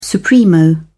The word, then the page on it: supremo: British English